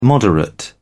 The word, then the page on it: moderate: British English